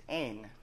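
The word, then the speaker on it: ein (ἦν): This word in the Greek means ein. That's how you pronounce it.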